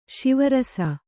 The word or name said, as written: ಸ್ವರಸ